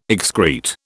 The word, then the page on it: excrete: British English